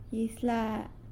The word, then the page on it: Izyla: Fine.